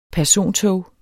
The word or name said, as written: persontog